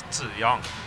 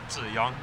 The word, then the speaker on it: Ziyang: Wordt mijn naam uitgesproken als Ziyang. En dat klinkt als John. Ziyang, John.